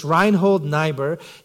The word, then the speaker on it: Reinhold Niebuhr: I called Karin, who's our German member here, uh, and asked her how to pronounce this. It's Reinhold Niebuhr.